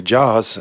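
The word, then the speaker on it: JAWS: джоз или джаз. Э, в общем, как вам нравится, так и говорите, наверное.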